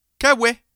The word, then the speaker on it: Cauet: Allons faire un tour en France cette fois avec Sébastien, je vais essayer de bien prononcer son nom, Caouet, C-A-U-E-T, et il nous fait une chanson de Noël et tout.